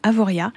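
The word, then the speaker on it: Avoriaz: Au fil de la prononciation, le nom est devenu Avorée, puis Avoréa, et puis finalement, pour devenir fameux Avoria, où, encore une fois, attention, on ne prononce pas le Z.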